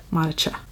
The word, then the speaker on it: Monicha: Either that or they pronounce it with the CH becomes like Monica. Cheese. So it's Monica. – Monica. – But I was like, no, it's CH like cholesterol.